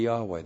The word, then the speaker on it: Yahweh: If you pronounced it, it would be Yahweh.